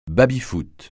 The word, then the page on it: baby-foot: francais